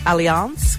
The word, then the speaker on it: Alliance: Now, apparently it's supposed to be pronounced Alliance because it was made initially by a French patisserie chef in Tasmania.